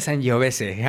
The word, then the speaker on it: Sangiovese: Y ojo, se pronuncia Sangiovese.